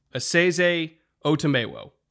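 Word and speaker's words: Esezi Otomewo: So it is Asese Otemewo.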